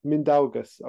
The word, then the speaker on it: Mindaugas: It's Mindaugas, but no offense taken. – Okay. Mindaugas.